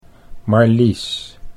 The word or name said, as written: Marlies